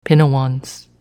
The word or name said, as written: Pinewans